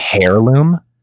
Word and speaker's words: heirloom: Did you just say heirloom? – Heirloom, whatever. – Heirloom. The H is silent.